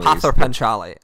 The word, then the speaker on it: Pather Panchali: I might be mispronouncing it, but I think it's Pather Panchali. – Pather Panchali is how I always read it.